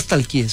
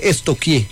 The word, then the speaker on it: estoquear: Porque no se dice nunca stalkeé, se dice estoquié. – ¿Estoquié? Sí. – Pregúntele a la profe, verá. A mí una vez estamos estoqueando. – Sí, estoqueando. Yo, pero se escribe stalkear. Es estoquear.